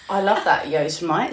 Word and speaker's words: Yosemite: Mate, don't get me started on Yosemite, which I only found out recently was Yosemite.